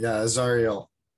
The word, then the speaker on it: Azariel: Azariel.